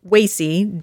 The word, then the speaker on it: Wacey: And you got to listen to the broadcast because it sounds like they're pronouncing it wrong. But no, it isn't. Wacy Rabbit. – Wacy, W-A-C-E-Y, Rabbit.